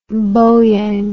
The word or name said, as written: bowyang